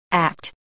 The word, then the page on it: act: English